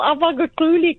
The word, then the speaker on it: Abaga Kuli: You said it so well, Abaga Kuli, because normally everybody's like, how do you say it?